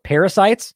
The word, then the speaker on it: pericytes: Now, weirdly enough, it's pronounced parasites for the podcast. I looked it up even. It's spelled P-E-R-I-C-Y-T-E-S.